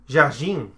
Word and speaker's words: Jardim: Jardim.